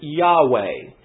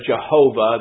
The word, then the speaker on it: Yahweh: We pronounce it Yahweh. The Germans give it a Jehovah. They give that J sound to it.